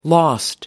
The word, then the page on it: lost: American English